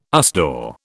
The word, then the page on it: USDAW: British English